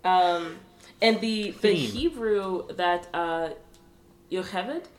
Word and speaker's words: Yocheved: Yocheved, apparently, Yocheved is the Hebrew pronunciation.